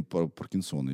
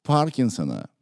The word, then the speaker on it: Паркинсон: Джеймс Паркинсон, английский Паркинсон. врач. – Поэтому, если бы он был француз, тогда был бы Паркинсон. А у меня здесь стоит ударение на букве О. Это не совсем верно.